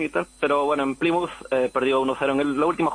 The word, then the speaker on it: Plymouth: Escucha, ¿cómo se pronuncia? Porque yo he oído que se dice Plymouth y Bournemouth. – Es Plymouth, Bournemouth y Portsmouth.